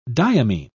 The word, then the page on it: diamine: British English